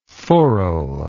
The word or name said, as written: forrel